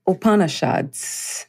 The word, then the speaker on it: Upanishads: You also mentioned a quote from the, uh, I'm not sure if I'm going to pronounce this correctly, Upanishads. Is that how you mm-hmm. – pronounce it? – Yes.